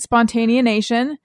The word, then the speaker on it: Spontaneanation: It's kind of, I was just listening to the most recent Spontaneanation.